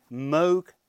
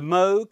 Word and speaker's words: Moog: He had a little label in his office, on his desk, saying it's Moog, rhymes with rogue, not Moog, rhymes with fugue.